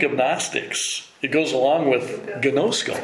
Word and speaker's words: Gnostics: Gnostics is, yeah, G-N-O-S-T... G-N-O-S-T-I-C-S. Gnostics.